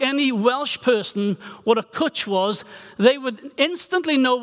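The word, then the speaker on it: cwtch: I won't ask you to pronounce it, but it's the word is kutch, and it rhymes with butch. So you know how to pronounce this Welsh word.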